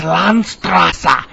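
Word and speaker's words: Landstraße: How do you say that? – Ah, that's just Landstrasse. – Okay. – It means street of land.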